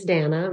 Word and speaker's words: Dana: My real name is Dana, rhymes with banana, and this is the Accidental Farm Podcast.